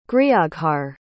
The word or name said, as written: grioghar